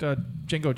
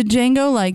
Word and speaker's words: Django: Isn't that the Django, DJ or whatever? – But the D is silent.